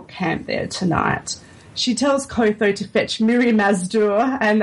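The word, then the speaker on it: Qotho: And Kotho, that's how I pronounce it, it's sort of like Clotho, one of Drogo's blood riders, he's quick to point out that a Khal who cannot ride is no Khal.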